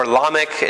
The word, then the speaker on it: Lamech: I might say Lamech and or Lamech. It's pronounced. You can pronounce it either way, but.